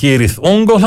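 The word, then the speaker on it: Cirith Ungol: Είναι εκείνος ο πύργος, εκείνο το πέρασμα, το οποίο από ό,τι λένε οι μελετητές τώρα του Tolkien προφέρεται Kirith Ungol, αλλά το συγκρότημα προτιμά να το λέμε τον δικό του τρόπο.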